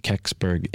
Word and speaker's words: Kecksburg: If anyone looks it up on Wikipedia, it's spelled K-E-C-K-S-B-U-R-G. Keksberg incident, and that's Keksberg, Pennsylvania.